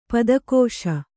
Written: ಪುದುಗಿಸು

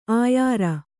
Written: ಆಯಾರ

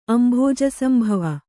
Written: ಅಂಭೋಜಸಂಭವ

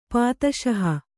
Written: ಪಾದುಶಾಹ

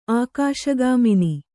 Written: ಆಕಾಶಗಾಮಿನಿ

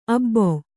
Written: ಅಬ್ಬೊ

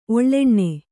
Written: ಒಳ್ಳೆಣ್ಣೆ

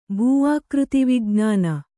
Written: ಭೂಆಕೃತಿವಿಜ್ಞಾನ

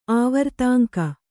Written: ಆವರ್ತಾಂಕ

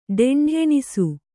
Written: ಢೆಂಢೆಣಿಸು

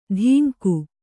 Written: ಧಿಂಗು

